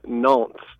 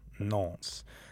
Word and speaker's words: Nantes: Could you pronounce that for me? – Yeah, they come from a place called Nantes. – Nantes.